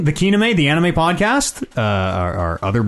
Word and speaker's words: Kenime: K-E-N-I-M-E. You gotta spell it, because it looks like it should be Kename. It's Kename.